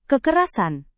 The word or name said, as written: kekerasan